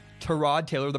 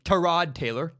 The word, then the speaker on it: Tyrod Taylor: We've been mispronouncing Tyrod Taylor, the Browns quarterback's name, for years, apparently. I've been saying Tyrod Taylor, and apparently that's totally wrong. His name is Tyrod Taylor, not Tyrod Taylor.